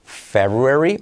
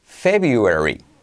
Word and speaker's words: February: Curioso con febrero que tiene dos formas de pronunciación. February o February, la que prefieras.